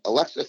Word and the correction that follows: Alexia: Philip, who I probably mispronounced her name, Alexia, I should say, she did a tremendous article, and I trusted her as a writer for the Hartford Current.